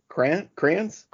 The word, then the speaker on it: Kranz: I don't know who Fran Crusher is. What did you say his name was? – Fran Kranz? K-R-A-N-Z.